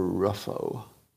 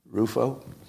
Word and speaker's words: Rufo: R-U-F-O, is it Rufo or Ruffo? Does anybody know? I'd like to say it right. Ooh? Rufo? Okay, I think it's Rufo. But he likes dunking on Ibram Kendi, who are, let's say, what would be the right word?